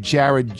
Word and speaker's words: Jarad: If the littles want to keep up with my adventure, they can follow me on Instagram at Jared, J-A-R-A-D, 2112.